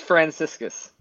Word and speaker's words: Franciscus: that is Franciscus.